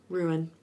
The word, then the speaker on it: Ruhn: I think we're going to see more of, what's his name, Ruin? – Ruin. – Ruin. We're going to wait because it's spelled weird. We're going to see R-U-H-N.